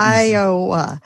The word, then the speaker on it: Iowa: Can you help Iowa.